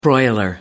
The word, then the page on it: broiler: American English